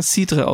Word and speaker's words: Cidre: Weil im Französischen wird da sogar Cidre ausgesprochen. – Genau, der Cidre. – Und in England Cider.